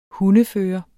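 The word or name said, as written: HUNDEFØRERE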